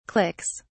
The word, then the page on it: clicks: British English